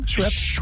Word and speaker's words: Schweppes: Schweppes.